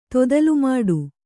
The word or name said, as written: ತೊದಲುಮಾಡು